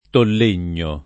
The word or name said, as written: Tollegno